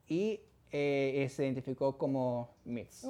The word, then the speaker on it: Mx: Y en vez de ser un mister o miss, son mx, pronunciándolo como mix o mods.